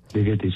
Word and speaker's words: Ligeti: Ligeti, sí, le insistía en la búsqueda de la originalidad.